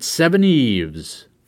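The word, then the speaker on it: Seveneves: That's right, you pronounce it Seven Eves.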